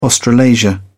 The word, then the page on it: australasia: British English